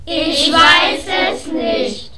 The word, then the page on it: ich weiss es nicht: German